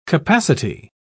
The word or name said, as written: capacity